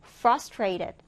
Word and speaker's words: frustrated: 첫 음절에 강세를 두고 frustrated, frustrated 이렇게 발음해 주는데요.